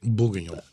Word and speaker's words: Bourguignon: Bourguignon.